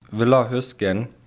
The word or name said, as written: Villa Huesgen